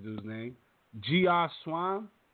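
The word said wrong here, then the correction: Geoff: Jeff Swan. His name is Jeff. That's how you say Jeff.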